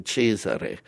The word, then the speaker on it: Cesare: At that time, I used my Italian pronunciation, Cesare, and they couldn't pronounce Cesare Frustaci.